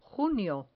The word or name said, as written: junio